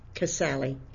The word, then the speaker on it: Casale: Casale. – Can you spell that, please? – C-A-S-A-L-E.